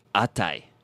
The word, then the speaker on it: atai: No, it rhymes with necktie. – Oh, I thought we were doing atashi, but... – it's very similar. On. It's actually atai. – Atai. – Yeah. – Oh. – If any of you people... – It doesn't rhyme with a tie. It's literally a tie.